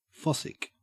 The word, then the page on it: fossick: Australian English